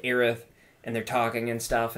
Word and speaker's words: Aerith: They're pronouncing it Aerith. Which is correct. – Which is correct, like, Japanese, in the Japanese culture.